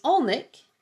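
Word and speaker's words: Alnwick: And number 12 is Alnic, and it is not spelled the way it's pronounced.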